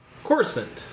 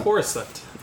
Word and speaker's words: Coruscant: Coruscant. Oh. Coruscant.